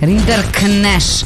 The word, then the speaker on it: Rinderknecht: Rinderknecht.